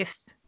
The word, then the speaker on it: Ralph: His mother's brother, Uncle Ralph. – Uncle Rafe. – Rafe, Rafe, sorry. Rafe. It's spelled Ralph, but yeah. – They're British.